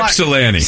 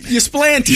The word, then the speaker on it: Ypsilanti: So you Ypsilanti. – Same thing, man. You know what I'm saying? – What did he call it? – Ysplanty.